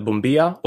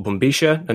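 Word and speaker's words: bombilla: It's called a bumbia or bumbisha. And there are, you know, various pronunciations of this word.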